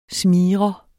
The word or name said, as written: SMIGREREN